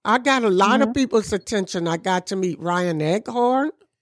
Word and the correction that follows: Eggold: I better get his name right. – Eggold. – Ryan Eggold.